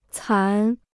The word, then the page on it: 残: cán